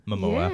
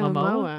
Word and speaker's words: Momoa: Momoa. – Yeah, Momoa.